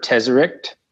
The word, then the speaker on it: Tesseract: I think they prefer Tesseract.